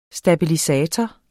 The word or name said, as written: stabilisator